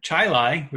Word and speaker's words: Chili: Well, in Rochester, we have Chilai, which is spelled chili. That's weird.